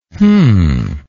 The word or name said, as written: hm